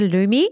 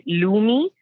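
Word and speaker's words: loomi: It's a lumi.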